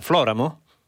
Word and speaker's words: Floramo: Qualcuno corregge la mia pronuncia? Floramo? – Va bene, sì, uguale, sono un nano, sono sdrucciolo come tutti gli altri. – va bene. Allora, io ringrazio molto Angelo Floramo.